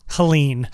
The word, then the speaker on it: Helene: Is it Helene? Helen? How do you say it? – Oh my God. You and pronouncing words. Helene. – Helene. Okay.